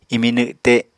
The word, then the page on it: imi nwte: apatani